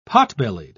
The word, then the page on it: potbellied: British English